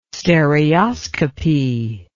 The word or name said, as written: stereoscopy